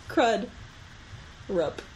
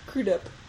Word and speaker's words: Crudup: Crud. Rup. – Say it all together. – Crudup.